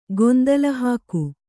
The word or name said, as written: ಗೊಂದಲಹಾಕು